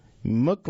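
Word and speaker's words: Muuk: Mook Labs develop AI-powered no-code software platform, and that's spelled M-U-U-K-L-A-B-S, Labs.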